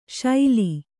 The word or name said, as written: ಶೈಲಿ